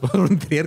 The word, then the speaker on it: von Trier: Gracias, Lars Born Tier. – Pero Eso no se dice muy seguido, por cierto. – Gracias, Lars von Trier.